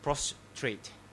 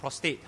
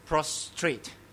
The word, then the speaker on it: prostrate: "The most effective position for prayer is to be prostrate. Now don't mispronounce the word. It's not prostate, all right? Okay. It's prostrate.